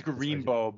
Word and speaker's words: Gorimbo: Gorimbo, am I saying that right? – Yeah, I think it's Gorimbo, but Gorimbo. Yes.